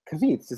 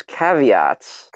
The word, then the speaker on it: caveats: Kavits. Is that what it's pronounced? – I thought it was caveats. – Caveats? What the fuck? – We could. Serious? One of us is looking fucking insane right now. I've never heard Kavits.